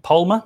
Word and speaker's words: Palma: Uh, Palmer is Pal- Palma, maybe I should pronounce that.